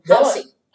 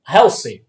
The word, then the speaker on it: Halsey: Helsey. – Halsey.